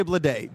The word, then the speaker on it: Bleday: Bladé. – Uh-huh. Mm-hmm. – The Marlins select J.J. Bladé. B-L-E-D-A-Y.